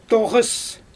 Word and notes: toɣɯs: Xyzyl

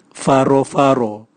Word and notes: varovaro: Raga

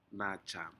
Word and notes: najam: Aneityum

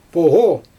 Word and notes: poGo: Xyzyl

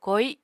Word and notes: koi: Apatani

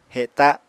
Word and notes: heta: apatani

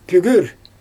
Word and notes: pügür: Xyzyl

